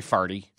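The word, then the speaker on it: forty: And, or as they say in Missouri, Highway Farty. And that's how they say it. – Do they? – Around St. Louis, Highway Farty Far.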